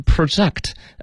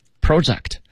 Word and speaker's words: project: 景观对，前面这个词呢，就是 projection，大家要再来看一下啊，projection，我们知道这个投影仪， projector，投影仪，那么这个 project，我们学过它的名词叫 project，项目，project，那么做动词的时候呢，一般读成 project， project 动词，什么意思呢？就是预测的意思，比如说 it is projected that。